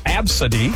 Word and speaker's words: Abcde: You know, like A, B, C, D, E, pronounced Absody.